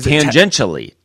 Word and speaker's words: tangentially: Yeah, first of all, you're not saying it right, so I have no idea. – I started saying it, and then I just couldn't say it. Tangentially. – Like Tasmanian? – No, like a tangent.